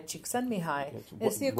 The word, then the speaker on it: Csikszentmihalyi: There's this concept of flow pioneered by an Eastern European thinker named Mihaly Csikszentmihalyi.